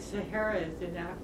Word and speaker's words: Sahara: You know, they don't pronounce Sahara like the way we pronounce it in Africa. It's like Sahara.